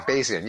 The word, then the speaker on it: Bayesian: Bayesian, yes, Bayesian. – Thank you. – I used to think it was Bayesian too until I heard someone actually pronounce it. It's named after the Reverend Thomas Bayes.